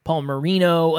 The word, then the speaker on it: Palmieri: I've made jokes in previous episodes where people mispronounce my name all the time. Paul Marino. It's just a lot of ways to pronounce my name.